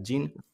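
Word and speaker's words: Gin: He probado Go, que tiene un framework que se llama Jin, escrito Jin, creo que se pronuncia Jin.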